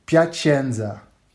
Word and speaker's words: Piacenza: Maar hoe spreek je het uit dan? – Piacenza. – Piacenza, oh ja, nou ja.